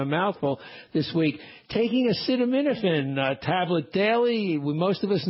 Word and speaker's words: acetaminophen: Study this week about acetaminophen. Have I pronounced that right? – Acetaminophen. Close.